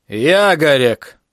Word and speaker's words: Ягорек: «Ягорек!» Он сделал пронзительное ударение на первом слоге.